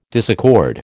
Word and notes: disaccord: American English

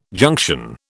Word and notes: junction: British English

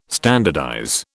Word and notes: standardize: British English

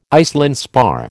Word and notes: Iceland spar: American English

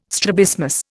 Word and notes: strabismus: Indian

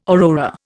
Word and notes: aurora: Indian

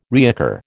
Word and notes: reoccur: American English

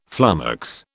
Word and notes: flummox: British English

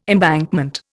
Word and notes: embankment: Indian

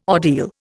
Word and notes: ordeal: Indian

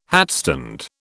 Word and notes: hatstand: British English

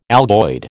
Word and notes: algoid: American English

